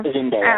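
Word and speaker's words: Zende: how do you say his name, Zenday? I don't know. – Zenday.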